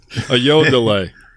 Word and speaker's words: Ayodele: Ayodele.